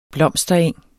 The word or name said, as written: BLOMSTERENG